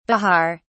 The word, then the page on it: bihar: British English